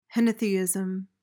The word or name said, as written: henotheism